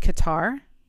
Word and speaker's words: Qatar: I think this is pronounced Qatar. Q-A-T-A-R.